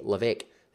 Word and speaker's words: Levesque: Levesque. That is T-R-A-C-Y. L-E-V-E-S-Q-U-E. That's right. Levesque has a silent S.